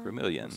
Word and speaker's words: Gremillion: So John Gremillion, and the last name caught me by surprise on the pronunciation, yeah. but it's G-R-E-M-I-L-L-I-O-N. – It looks like Gremillion. – Gremillion, like but it's Gremillion.